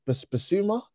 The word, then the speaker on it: Bissouma: Is it Bissouma, the midfielder? – Yeah.